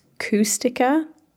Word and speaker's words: Kushtaka: So, the Kushtaka, often pronounced Kustika, i.e.